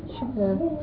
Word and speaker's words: cheveux: How do you say hair? – Cheveux.